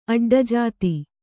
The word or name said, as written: ಅಡ್ಡಜಾತಿ